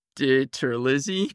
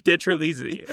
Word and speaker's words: DiTerlizzi: And how do you pronounce his name? – Tony DiTerlizzi. – DiTerlizzi, okay.